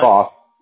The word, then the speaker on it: Boss: Boss. B-O-S-S.